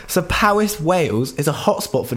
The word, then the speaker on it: Powys: Powys. It's spelled P-O-W-Y-S.